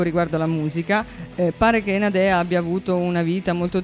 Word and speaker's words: Nadeah: mentre la musique si sta per crollare, che sono stanchissima stasera, vi voglio parlare di Nadea, che non è Nadea in romano, ma in realtà è Nadea. – Nadea è una cantante australiana e si scrive N-A-D-E-A-H, come tipo Deborah con l'H, insomma.